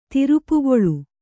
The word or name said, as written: ತಿರುಪೆಕೂಳು